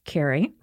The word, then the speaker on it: Kerry: So when I was in high school, some people used to sort of, as a nickname, call me Carrie instead of Karen. But see, I like that spelled with a K, not a C. So like I would spell it K-E-R-R-Y, which is the Irish Carrie.